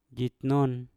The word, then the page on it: jinon: Mpur